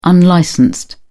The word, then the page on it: unlicensed: British English